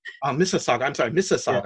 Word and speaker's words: Mississauga: So, I know Mississauga is the neighboring city next to Toronto. – Mississauga. – Mississauga.